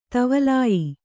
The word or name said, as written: ಧಾವಲ್ಯ